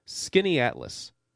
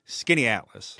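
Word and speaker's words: Skaneateles: Skinny Atlas, by the way, in case folks are not familiar with the Finger Lakes or that part of New York, Skinny Atlas, it sounds like S-K-I-N-N-Y-A-T-L-A-S, Skinny Atlas. That is not at all how this is spelled, right, Guy? – No, and it took me a while to properly spell it in my pre-trip notes, but it's S-K-A-N-E-A-T-E-L-E-S. – Just like it sounds.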